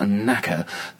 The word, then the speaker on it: Naka: I think it's pronounced Naka. I'm going to spell it. It's N-A-K-A, and I say Naka, and it might bring a smile to certain people's faces out there, but that's what I think it is.